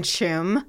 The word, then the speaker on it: Tuam: And she was like, uh-huh, you mean Chum? – Yeah, Chum. – Chum. Okay, next one, E-O-I-N.